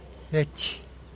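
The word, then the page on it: gech: Teotitlán del Valle Zapotec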